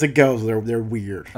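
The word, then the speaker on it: Gose: From the can, I can see it's spelled like rosé. – Yeah, G-O. – But it's G-O-S-E. – Yep. – So, Goza? – I have no idea. – Goza? – It's a Goza.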